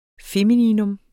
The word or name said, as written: FEMININA